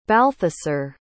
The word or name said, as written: balthasar